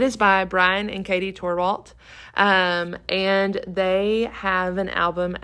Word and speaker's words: Torwalt: Torwalt. – Torwalt. – T-O-R-W-A-L-T.